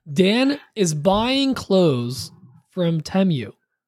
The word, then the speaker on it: Temu: He had a deal with them, and they apparently sent the audio file of how to pronounce it, and it's Temu.